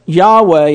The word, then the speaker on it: Yahweh: Now, ancient Hebrew has no vowels. And even the oldest manuscripts do not help us know how to exactly say it. But Yahweh is probably the nearest pronunciation of way to say that name.